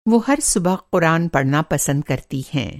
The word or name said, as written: क़ुरान पढ़ना